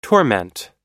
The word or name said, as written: torment